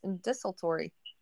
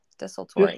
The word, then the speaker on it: desultory: Yeah, desultory. – Yeah. – D E S U L T O R Y. Desultory. – Desultory. Yes, mm-hmm. Three syllables. Desultory.